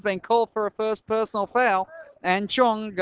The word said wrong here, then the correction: Chang: going to the line. Chang. Sorry about the pronunciation.